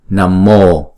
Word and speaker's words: Namo: Cái chữ Namaha nếu mà đứng một mình thì chúng ta đọc là Namaha ha. Nhưng mà theo sau nó là chữ P thì chúng ta đọc là Namo, Namo Padma ha.